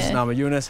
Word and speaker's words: Namajunas: Rose Namajunas. Just kidding. You probably get that a lot. Rose Namajunas, how you doing? – Oh, thank you.